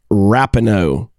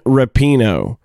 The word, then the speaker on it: Rapinoe: I've never heard her called Rapinoe. Megan Rapinoe.